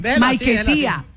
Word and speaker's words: Maiquetía: Ella se presentó ahí en el aeropuerto Simón Bolívar de Maiquetía. ¿Lo dije bien? – Maiquetía. – Pues si lo dije bien, entonces, ¿para qué me corrige? – No lo dijo mal.